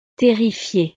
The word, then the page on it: terrifie: frances